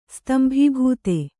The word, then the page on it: ಸ್ತಂಭೀಭೂತೆ: stambhībhūte